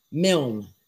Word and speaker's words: Milne: Milne, M-I-L-N.